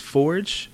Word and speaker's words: Feorge: It's pronounced exactly how it's spelled. F-E-O-R-G-E. – Almost, it's George with an F.